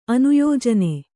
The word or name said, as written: ಅನುಯೋಜನೆ